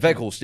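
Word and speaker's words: Weghorst: It's pronounced Virgos, isn't it? Because he's Dutch. – Virgos, yeah.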